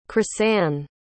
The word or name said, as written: chrysann